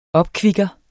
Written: opkvikker

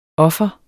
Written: offer

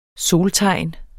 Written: soltegn

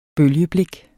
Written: bølgeblik

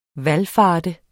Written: VALFARTENDE

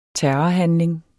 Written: terrorhandling

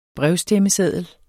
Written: brevstemmeseddel

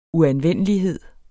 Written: uanvendelighed

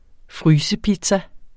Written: frysepizza

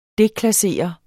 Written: deklassere

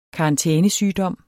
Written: karantænesygdom

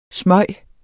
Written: smyge

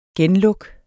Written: genluk

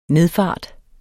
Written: nedfart

